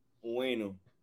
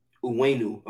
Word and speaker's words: Onwenu: How do you say it? – Onwenu. – Onwenu, okay.